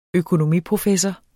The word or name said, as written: økonomiprofessor